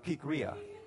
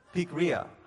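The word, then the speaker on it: Pikria (πικρία): And that Greek word for bitterness is pronounced, not Pikachu, but Pikria. Pikria.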